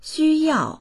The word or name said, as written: xu1yao4